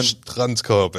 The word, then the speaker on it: Strandkorb: So how does it, what's the German? – Strandkorb. Again, that's what I said. – You're getting there. Next one. – Strandkorb.